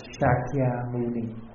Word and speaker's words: Shakyamuni: Usually we pronounce it Vietnamese, Thích Ca Mâu Ni. Thích Ca Mâu Ni is the translation, is the pronunciation from the original term Shakyamuni.